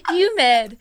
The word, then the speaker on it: humid: You're pronouncing it Y U M. – Humid. – No, there's an H.